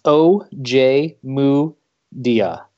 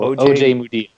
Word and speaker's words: Ojemudia: So I've got my Iowa media guide right here, the 2017 media guide, and it's pronounced O.J. Mudia. Moo is where your accent is. So O.J. Mudia. – O.J. Mudia.